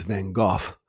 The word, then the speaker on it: Van Gogh: And I saw the Monets, the Manets, the Rembrandts, and my favorites, the proper pronunciation is Van Gogh.